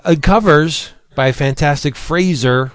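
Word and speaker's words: Frazer: Because when we first met him back in the bullpen days, he said Fraser rhymes with laser.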